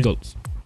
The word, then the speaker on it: Kindles: Kindles.